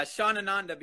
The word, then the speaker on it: Shanananda: Nah, Shanananda, because Shanananda. – So Sean's my name. Ananda means bliss. So.